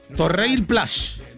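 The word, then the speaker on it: Torreilles Plage: Torreil Plage, así se pronuncia. En francés, obviamente, hay que afinarlo más para el francés, pero en español sería Torreil Plage, este lugar.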